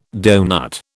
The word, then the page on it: donut: British English